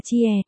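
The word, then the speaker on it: chia (切): kêu ti dịch là thiết có nghĩa là cắt đọc là chia, mà từ này phát âm gần giống xía trong tiếng Việt mình nên mình thay thế vào, v.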